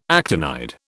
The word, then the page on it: actinide: British English